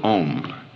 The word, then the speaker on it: Om: Now in Sanskrit, the syllable Om, the sound O, is analyzed into two sounds, A and U joined, A, U, M. So you'll sometimes see this written O-M and sometimes A-U-M. When written A-U-M, it is called the four-letter sound. I'm going to explain what the four letters are. When you pronounce this syllable, ah starts in the back of the mouth. Oo, the sound fills the mouth cavity, and then m, it closes at the lips.